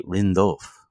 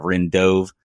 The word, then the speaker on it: Rindov: He like really kind of Americanizes it, but he told me one on one that it's really Rindov with more of like an F sound at the end.